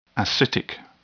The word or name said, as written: ascitic